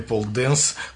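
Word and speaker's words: pole dance: и вместе с ней защищаем имя пол-дэнс к спорту больше, чем к стриптизу. – Пол-дэнс. Пул – это пол, нет? – Как пишется поле. Пол дэнс. – Пол, хорошо.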